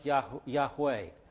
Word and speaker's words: Yahweh: Or as it's probably pronounced Yahweh or Yahweh. Yahweh probably. The Vav can also have a W sound to it.